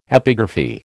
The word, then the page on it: epigraphy: American English